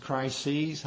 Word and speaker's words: crises: It's actually pronounced crises. I'm not used to saying it quite like that. I've heard it pronounced that way. I usually say Christ. I don't know. Some dictionaries say you can use either one, but this one just had crises, a long E.